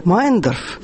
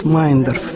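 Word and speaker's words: Мейендорф: Это мемуары Марии Федоровны Мейндорф. Фамилию они именно так произносят, Мейндорф. Все пытаются произнести так, как пишется, но на самом деле семья вся сама произносит Мейндорф.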